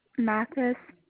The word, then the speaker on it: Mathis: You said H-A-L-E-Y? – Yes. – Can I have your last name, please? – Mathis, M-A-T-H-I-S. – N-A-C-H-I-S? – No.